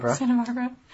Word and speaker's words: Santa Barbara: Is that how you say it? – Santa Barbara. – She's been named Outstanding Performer of the Year by Santa Barbara. It's not Santa. Santa Barbara.